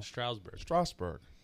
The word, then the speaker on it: Strasburg: Strasburg. – There's Harper's no U in it. – Bryce Harper. I mean, Steven Strasburg. – It's not a Stroud. There's no U in it. It's Strasburg.